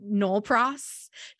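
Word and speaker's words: nolle pros: And we learned, I think it was our last story where we learned about the thing I can't pronounce and that you said is just Nolpros, just called Nolpros in the biz.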